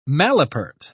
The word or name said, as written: malapert